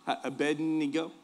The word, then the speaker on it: Abednego: Abednego.